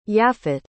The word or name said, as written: yaffit